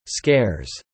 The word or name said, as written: scares